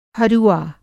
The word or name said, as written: हरुआ